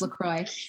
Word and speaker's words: LaCroix: The best way I've ever spent to South by Southwest, and just like just a little LaCroix, and which is how Canadians pronounce it, even though I know it's LaCroix.